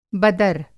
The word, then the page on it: बदर: Hindi